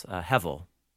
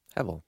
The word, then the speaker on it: Hevel: Hevel. – Hevel. – Hevel. H-E. It's technically the letter B of the Hebrew alphabet, but it pronounces like a V. So Hevel. Hevel.